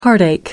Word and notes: heartache: American English

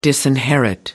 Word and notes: disinherit: American English

